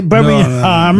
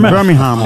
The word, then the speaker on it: Birmingham: Voilà, on ne prononce pas le G, Birmingham. – Birmingham.